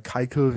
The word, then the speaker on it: Keuchel: Ähm, und Dallas Keuchel oder Keikel, wie er in Amerika ausgesprochen wird, hat ein ERA Plus von 662.